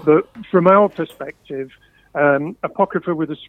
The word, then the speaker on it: Apocrypha: A lot of what's on the album, the album's called Apocrypha, by the way.